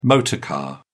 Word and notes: motor car: British English

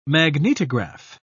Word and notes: magnetograph: British English

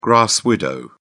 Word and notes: grass-widow: British English

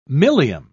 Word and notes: milium: British English

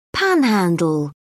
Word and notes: panhandle: British English

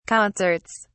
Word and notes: concerts: British English

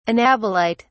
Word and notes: anabolite: British English

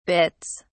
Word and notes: bitts: British English

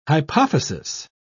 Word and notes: hypophysis: British English

